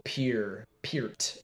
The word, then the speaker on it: Peart: And so, like, if you were to say Neil Peart, you would spell it P-E-R-T, but it's P-E-A, so Peart.